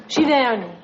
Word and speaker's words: Giverny: Diane, am I pronouncing this right? Giverny? – Giverny. – Thank you.